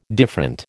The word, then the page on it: different: American English